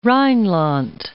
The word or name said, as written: Rheinland